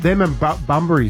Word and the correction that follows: Brumbies: Is that how you say it? – Brumbies.